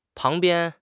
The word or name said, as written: pang2 bian1